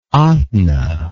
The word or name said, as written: Ahtna